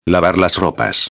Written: lavar las ropas

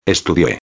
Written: estudie

